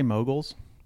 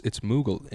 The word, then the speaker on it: Mughal: Are you trying to say moguls? – No, it's Mughal. M-U-G-H-A-L. – Oh. Yeah. – Hey, you bitch. – I thought you were just mispronouncing mogul.